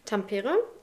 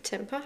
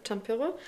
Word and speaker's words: Tampere: Um genau zu sein, in Tampere. Ich meine, spricht man so aus. Temper habe ich erst gesagt, ich glaube Tampere.